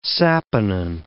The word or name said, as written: saponin